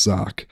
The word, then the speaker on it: Zak: Mine is spelled Z-A-K, and everyone calls me Zach. It is pronounced Zach.